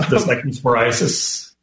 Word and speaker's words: cyclosporiasis: I'm going to probably say it wrong, the cyclosporiasis outbreak. – The cyclosporiasis. – Yep. Okay, very thank close.